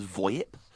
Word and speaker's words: VoIP: Well, the generic would be Voice Over Internet Protocol, or I guess you could pronounce it as VoIP.